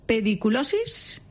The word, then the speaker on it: pediculosis: de la pediculosis.